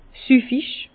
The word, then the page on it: süffig: Bas-Rhin; Herrlisheim